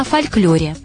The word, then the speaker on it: фольклор: Кстати, о фольклоре. Два варианта произношения. Фольклор – л – твердый, и фольклор – л – мягкий. – Равнозначно. – Равноправные варианты. Фольклор и фольклор. Выбираете вы.